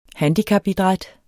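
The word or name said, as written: HANDICAPIDRÆT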